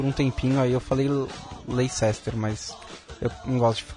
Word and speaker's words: Leicester: Hoje tinha no fim de semana que teve jogo do Leicester em casa, tinha um cachecol que tava L-E-S, tracinho, T-A-H, então é Leicester. É como Leicester.